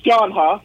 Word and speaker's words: John Huh: It's John Huh.